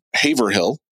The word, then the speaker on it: Haverhill: And still live in Haverhill. That's how you pronounce that one.